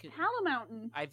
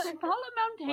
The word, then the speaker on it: Palmountain: It's Palmountain. – It's spelled like mountain. – Yeah, I know. I'm really dumb. I'm sorry. – Mountain. – Okay. Who cares? – Palmountain.